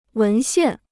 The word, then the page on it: 文献: wén xiàn